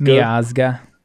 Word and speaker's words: Miazga: Um, is it Mi- uh, Mizaga? Miazga? – Miazga.